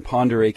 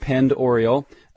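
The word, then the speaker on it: Pend Oreille: way up there in Ponderay County. And no, it's not pronounced Penned Oriole.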